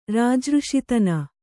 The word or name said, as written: ರಾಜಋಷಿತನ